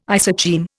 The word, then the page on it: isocheim: Indian